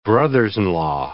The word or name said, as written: brothers-in-law